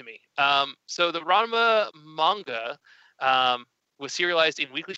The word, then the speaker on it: manga: Uh, we later found out, or I later found out it's pronounced manga and it's not the art of Japanese comic collecting.